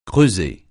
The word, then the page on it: creuser: francais